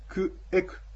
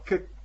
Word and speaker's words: ケク: ですからカラッパはケッ、ケクというのは自分が行ったということで、クエク、クっていうのは私、エクは行く。で、クエクがケクというふうに発音されます。ですから、あの、カラッパはケク、カラッパはケクって、これで行ってきたよということになりますね。